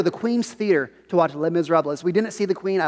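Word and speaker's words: Les Misérables: Hey, listen, if you want to be cultured, it's the S is silent. It's Les Miserables, not Les Miserables.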